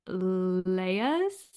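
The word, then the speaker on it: Lyre's: So, but I have and I actually just looked up how to pronounce it. It's an Australian brand, I guess, or it's inspired by an Australian bird or something like that. But it's a non-alcoholic brand called Lyres, which is spelled Lyre apostrophe S.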